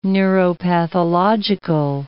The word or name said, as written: neuropathological